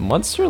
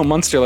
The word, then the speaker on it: Munster: Is it Munster, like a monster? Like no, like yeah, no, Munster, like the cheese or the place in Ireland.